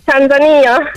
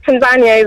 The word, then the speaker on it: Tanzania: I want to learn this because I say Tanzania, but you just said Tanzania. What? How do you say it? – Tanzania. – Oh, Tanzania. – That's how we say it, but Tanzania is also okay.